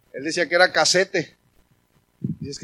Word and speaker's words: casete: no se dice cassette, se dice casete. Así decía él, decía casete. Entonces decía yo,